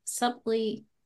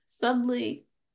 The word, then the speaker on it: subtly: The subtly. Well, why is there a B in there? – Because it's the English language. – Yeah. – Okay. This subtly.